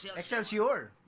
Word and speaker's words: Excelsior: Excelsior pala.